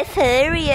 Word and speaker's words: Fury: Easy, a pronúncia dele é Fury, é Fury.